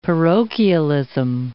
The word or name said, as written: parochialism